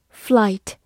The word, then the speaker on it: flight: Flight, F L I G H T, flight，字母 i 发它本身的音 i，g h 不发音。